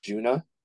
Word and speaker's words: Djunah: And they're called Juna. It's spelled DJUNAH.